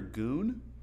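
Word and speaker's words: Gün: Gun or goon? – How you spell that? – G-U-N, but it has the dots over. – Goon. Yeah.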